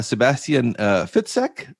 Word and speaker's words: Sebastian Fitzek: And on Zoom, I have Sebastian Fitzek.